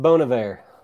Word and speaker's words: Bon Iver: I believe it's pronounced Bon Iver.